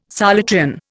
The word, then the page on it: Solutrean: Indian